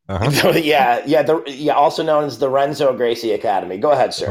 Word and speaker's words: Renzo: The R's in Brazil are pronounced as H's. – Brazilian? Brazilian jiu-jitsu? I'm just going to say Brazilian. – No. What's the name of the place? Did you say Renzo? – Yeah. – Yeah, that would be Henzo.